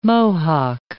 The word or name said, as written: Mohawk